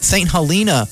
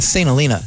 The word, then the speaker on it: St. Helena: Department of Agriculture's Farmers Market Promotion Program, the initiative unites the Napa Farmers Market, Kaiser Napa Farmers Market, Yountville Certified Farmers Market, St. Helena Farmers Market, I mispronounced it, St. Helena, that's how we say it, Farmstead Farmers Market, and Calistoga Farmers Market, and I did mispronounce that on purpose, under one regional platform.